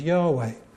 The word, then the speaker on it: Yahweh: often pronounced as Yahweh, or translated as Jehovah in the older versions of the Bible.